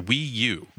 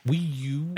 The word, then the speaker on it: Wii U: How do you say that? – It's the Wii U. – Wii U.